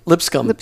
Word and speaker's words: Lipscomb: Lipscomb.